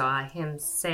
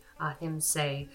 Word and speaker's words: ahimsa: But the first one we're going to look at is ahimsa, which is nonviolence, and actually that's a long A, so ahimsa, I apologize, ahimsa, nonviolence.